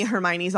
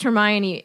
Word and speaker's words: Hermione: But to me, Hermione's always been Emma.